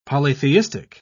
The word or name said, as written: polytheistic